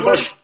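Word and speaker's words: Buechler: I mean, I think I botched the pronunciation there, but I don't know if anyone's ever gotten it right. Bushler. – Right? It's Bushler. Judd Bushler.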